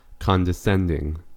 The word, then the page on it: condescending: American English